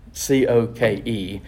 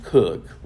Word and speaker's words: Coke: C O K E. In England, it's pronounced Cook. We pronounce it Coke here, and I'll show in a minute where that matters.